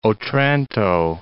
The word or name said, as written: Otranto